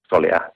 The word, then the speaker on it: soleá: Bueno, ya está admitido el término soleá, que ha costado mucho trabajo de que entre, un término flamenco, bueno, soleá, tal como suena, ¿no? Con el acento en la...